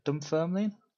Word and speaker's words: Dunfermline: Is it Dunfermline? – Is that right? That's right. – No, yeah. that, that's very good pronunciation, actually.